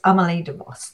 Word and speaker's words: Amelie DeVos: It's Amelie DeVos.